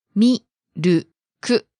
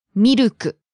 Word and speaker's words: ミルク: ミルク. Three syllables, right? – Right. you have to pronounce every syllable with the same length. – Say it one more time. – Mi-ru-ku. Mi-ru-ku.